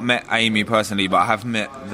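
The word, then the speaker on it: Aimee: And also, you hung out because Anime Is that how you pronounce her name? Anime of the Interrupters. – Oh, Amy.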